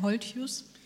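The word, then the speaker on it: Holthuis: Meine erste Frage, habe ich deinen Nachnamen richtig ausgesprochen? Friedhelm Holthius? – Auf Deutsch hast du es richtig ausgesprochen. Wenn du jetzt ein paar Meter über die Grenze fahren würdest, würdest du Holthaus sagen.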